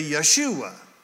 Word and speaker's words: Yeshua: How do you say Joshua in Hebrew? You say Yeshua.